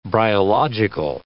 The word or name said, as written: bryological